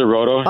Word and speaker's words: Rohto: So Roto, they pronounce it Roto.